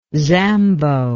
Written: zambo

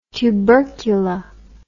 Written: tubercula

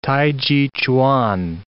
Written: t'ai chi

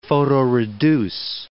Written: photoreduce

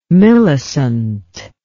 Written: millecent

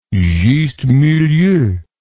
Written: juste milieu

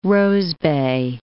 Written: rosebay

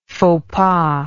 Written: fauxpas

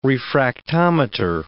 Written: refractometer

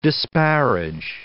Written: disparage

